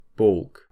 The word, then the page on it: baulk: British English